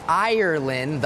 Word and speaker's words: Ireland: และมีคำหนึ่งค่ะที่น้องชมพู่สะกดได้ถูกเลยก็คือ Ireland ซึ่งเป็นคำที่ tricky มากๆเลยนะคะแต่เดี๋ยวเราไปถาม professor กันดีกว่าค่ะว่า tricky ยังไง So when you're listening to the word Ireland the R is important some people might hear Island or even Iceland